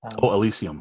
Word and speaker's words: Elysium: Elysium. Is that how you pronounce it? – Elysium.